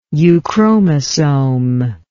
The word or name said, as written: euchromosome